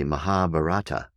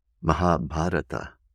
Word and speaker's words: Mahabharata: Bharata, B-H-A-R-A-T-A. Bharata, Mahabharata, all one word. Very often pronounced and mispronounced, even by academics in the West, as the Mahabharata. The Mahabharata. And that's okay if you need to say it that way in order to get the book. You can say Mahabharata. But its proper pronunciation is Mahabharata. Mahabharata.